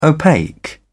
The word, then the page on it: opaque: British English